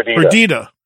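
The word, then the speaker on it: Perdida: Is it Perdita? How do you say it? – Perdita.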